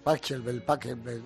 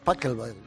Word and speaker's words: Pachelbel: Pachelbel, no sé cómo se dice. No, es que yo decía Pachelbel también. – Sí, pues. – Y luego me dijeron, no, es Pachelbel.